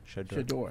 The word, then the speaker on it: Shedeur: Shedeur. – Shedeur.